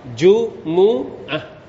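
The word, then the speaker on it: จุ มู อ ะ (جمعة): ภาษาอาหรับอ่านว่าจุมูอะตัวมีมต้องดอมมะพวกเราชอบอ่านจุมอะ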